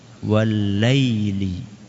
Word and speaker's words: والليل: Wadduha والليل إذا سجى والليل إذا سجى banyak keliru, walaili. Kadang-kadang bacanya walaili, lae. Ini ala Jawa. Ya. Kalau ala Arab apa? Walaili. Apa? Walaili. Bukan walaili.